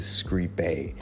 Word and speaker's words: scribe: So for instance, the imperative singular of scribo is scribe with a short E.